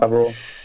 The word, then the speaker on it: Favreau: Favreau, uttalar han sig så? – Mm, ja. Favreau.